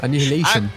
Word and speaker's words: Annihilation: Como é que se pronuncia esse nome? Meu Deus do céu. Doom Annihilation, é isso? – Annihilation.